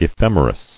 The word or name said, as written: Ephemerides